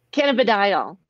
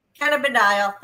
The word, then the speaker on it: cannabidiol: Cannabidiol. – Cannabidiol.